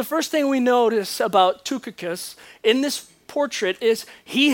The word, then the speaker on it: Tychicus: We begin verse 7 with Tychicus. It doesn't look like it should be said that way, but I have been assured by someone who speaks and reads the language that that's how you say it.